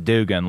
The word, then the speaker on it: Dugan: It's pronounced Dugan, like that big pink one slapping between your thighs, bro.